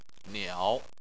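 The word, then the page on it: niao: tone 3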